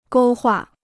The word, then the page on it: 勾画: gōu huà